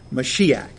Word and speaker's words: Mashiach: Mashiach. Did I pronounce that correctly this time, Lord? Okay, good.